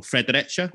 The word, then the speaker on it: Fredericia: So born in November 1965 in the city of, I'm going to get the pronunciation of this horribly wrong, Fredericia.